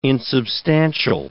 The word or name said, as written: insubstantial